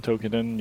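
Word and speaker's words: Toukiden: Tweakedon? Tokiden, yeah. – Tokiden.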